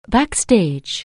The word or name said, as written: backstage